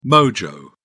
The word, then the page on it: mojo: British English